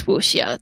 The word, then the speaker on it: Wuxia: or how do you pronounce it? – I think it's Wuxia,